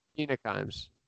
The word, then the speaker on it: Mina Kimes: I watched the other day, and it was Minka Kimes. Is that how you say her name? – Mina Kimes.